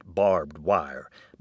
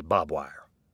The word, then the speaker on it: barbed wire: It's actually pronounced "barbed wire," but many of us, especially those of us have an intimate acquaintance with it on a daily basis, say "bob wire."